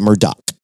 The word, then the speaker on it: Murdaugh: And it's spelled Murdaugh, but it is pronounced Murdaugh.